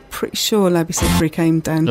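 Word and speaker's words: Labi Siffre: Labby Seafra? – Labby Seafra.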